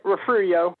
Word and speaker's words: Refugio: The G is pronounced like an H, right? In Refugio? – Yeah, Refugio.